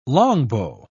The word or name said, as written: longbow